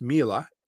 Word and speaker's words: Miele: it's pronounced Miele, but it's spelled M-I-E-L-E.